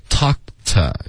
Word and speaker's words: talk: Okay, talk to 要注意，不是偷。 Okay, 它是 ta 这个嘴巴张开，偷他跟 talk to 区别很大。